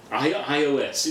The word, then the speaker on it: iOS: Tell us a little bit about this IS6. Am I pronouncing that right? I only ever read that. – iOS. It's iOS, operating systems.